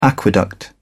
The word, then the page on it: aqueduct: British English